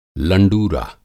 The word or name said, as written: लंडूरा